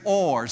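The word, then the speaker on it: or (אור): The Hebrew word that is used for light is or. Say it with me, or. And it's spelled phonetically in English, certainly not in the Hebrew, but in English it is spelled simply O-R, or.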